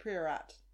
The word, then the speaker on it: Priorat: I would pronounce it Priorat. – Yeah, Priorat. – That's what I said. Yeah. Priorat. – Priorat. – Priorat. – Queer-eye. – Queer-rat. Queer-rat. It's a queer rat. It's a Spanish red that is from Morrison's, specially selected, so you know it's good shit.